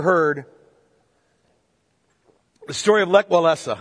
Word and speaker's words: Walesa: You pronounce it Walesa. If I move between those, understand, it's a Polish name.